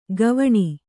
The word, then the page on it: ಕಾವಣ: kāvaṇa